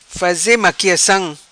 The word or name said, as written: Faze maquiacam